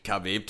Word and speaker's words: Khabib: Look at Habib. Look at Chimaev. Keep saying that guy's name wrong. But look, Khabib 2.0.